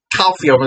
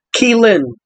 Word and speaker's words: Caoilfhionn: So it's spelled C-A-O-I-L-F-H-I-O-N-N. – So that's why we thought it was calcium or whatever the fuck. This is how it's actually pronounced. – Keelin.